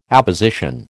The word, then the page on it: apposition: American English